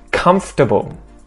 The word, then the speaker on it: comfortable: Comfortable. Comfortable. Sort of similar to vegetables, where we get rid of a syllable.